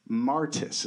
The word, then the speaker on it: martys: Martis is the way that the word is pronounced. It sounds like M-A-R-T-U-S, but it's spelled M-A-R-T-Y-S.